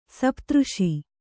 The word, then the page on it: ಸಪ್ತಋಷಿ: sapta řṣi